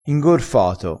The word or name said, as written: ingorfato